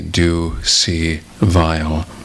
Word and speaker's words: Dewseavile: But it's spelled in many ways, and the way this title is spelled is D-E-W-S-E-A-V-I-L-E, Doozyville.